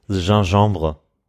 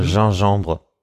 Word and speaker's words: gingembre: Gingembre. – Gingembre? – Ja, wird erst wie Gin geschrieben und dann nochmal Gembre. Gin, Gembre. – Mhm. – Gingembre.